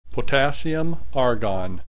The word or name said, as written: potassium argon